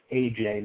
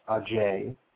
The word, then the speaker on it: Ajay: First of all, he mispronounces my name. It's AJ, not Ajay. You know, just because I spell it a different way doesn't mean you just don't pronounce it like you would pronounce AJ, but okay.